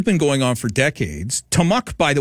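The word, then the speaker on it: Te'mexw: Tamuk is a word that means land. It's in Hulquitim, and it means land in English. And it's spelled out T-E-apostrophe-M-E-X-W.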